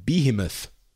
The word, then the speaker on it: behemoth: Podcasting behemoth. Is that how you pronounce it? Yeah. – Yes.